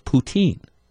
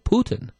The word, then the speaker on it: poutine: The E is silent, and it's like Putin, kind of like the Russian dictator, but, you know, but Putin, that's the E is silent.